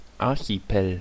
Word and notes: Archipel: ˌaʁçiˈpeːl; /ˌar.çiˈpeːl/; /ˌar.çiˈpeː.lə/

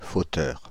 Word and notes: fauteur: Paris; [fo.tœʁ]; /fo.tœʁ/

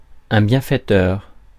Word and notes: bienfaiteur: France; [bjɛ̃.fɛ.tœʁ]

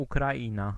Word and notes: Ukraina: /ukraˈin̪a/

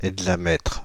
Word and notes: hexamètre: [ɛg.za.mɛtʁ]; /ɛg.za.mɛtʁ/; /ɛɡ.za.mɛtʁ/

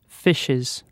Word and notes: fishes: British English; /ˈfɪʃɪz/

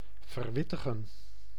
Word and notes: renseigner: France; [ʁɑ̃.se.ɲe]

